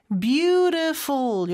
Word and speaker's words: beautiful: 그 다음에 beautiful인데 beautiful 이렇게 발음이 되죠. t는 모음 소리 사이에 껴서 리을처럼 발음이 돼요. 그래서 beautiful 그 다음에 F 발음은 윗니를 아랫입술에 살짝 댄 상태에서 푸라고 하는 것처럼 바람을 내뿜는 소리죠. 그래서 beautiful 이렇게 발음을 하고요.